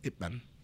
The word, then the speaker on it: Ip Man: Is it Ip Man or IT Man? I've heard both. – It's Ip Man.